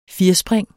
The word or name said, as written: firspring